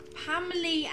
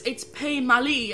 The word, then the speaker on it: Pamely: And Harry, who's reminding us how to say his name because we've never said it right, says, Pamely as in family. Now, that depends on how you say family, really. – Oh, so it's Pamely as in family. – Family, Pamely.